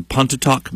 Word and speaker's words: Pontotoc: Weatherly was born in Pontotoc, Mississippi.